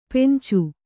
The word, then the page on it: ಪೆಂಚು: Kannada